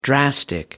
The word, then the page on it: drastic: American English